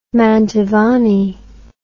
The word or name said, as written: Mantovani